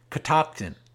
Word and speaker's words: Catoctin: Remember that name, Catoctin, C-A-T-O-C-T-I-N. Pronounce like you spell it.